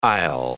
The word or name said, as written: aisle